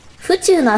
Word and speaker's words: 府中: 府中のアクセントは、宇宙じゃなくて、地球の方。